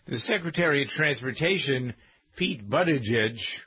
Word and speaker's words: Buttigieg: Boot, edge.